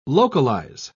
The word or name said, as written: localize